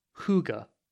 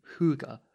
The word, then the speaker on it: hygge: So one word I love is the Danish word hygge. I think that's how you pronounce it. It's H-Y-Y-G-E, hygge.